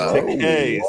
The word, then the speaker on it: Takei: Takei, sir. It rhymes with gay.